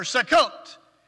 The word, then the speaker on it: Sukkot: The Feast of Booths, or Sukkot, is how you say it in Hebrew.